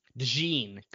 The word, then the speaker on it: DeJean: Cooper DeJean? – DeJean.